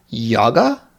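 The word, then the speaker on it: jogga: and the second verb is yaga. I imagine the pronunciation is like that yaga, which means to jog.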